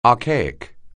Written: archaic

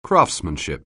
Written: craftsmanship

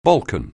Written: Balkan

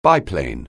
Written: biplane

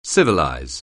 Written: civilize

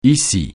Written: ici

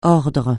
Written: ordre

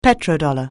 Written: petrodollar

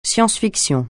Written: science-fiction

